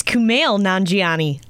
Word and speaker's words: Kumail Nanjiani: It's Kumail Nanjiani. I was close.